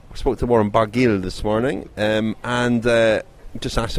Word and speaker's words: Barguil: Warren Barguil. I got a pronunciation lesson. Barguil. – You do. We checked with Francois Thomaso last night.